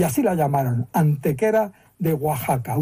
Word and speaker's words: Oaxaca: Entonces, esa palabra así escrita se pronunciaba Oaxaca. Y hoy también, porque se ha mantenido, fíjate, la grafía española del siglo XVI.